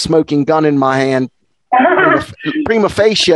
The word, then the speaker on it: prima facie: In English, it's pronounced prima facie. Prima facie. In Latin, it's prima facie.